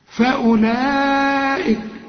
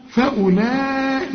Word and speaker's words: فأولئك: شوف فأولئك، ما تقولش فأول، لا فأولئك، وده مد واجب بقى عند الوصل وعند الوقف، المد والهمزة في الكلمة واحدة، هم الخاسرون.